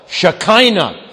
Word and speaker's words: Shekinah: And you could pronounce, changing the vowels slightly, the dwelling glory of God, Shekinah. You change the vowel, Shekinah. It's the same word.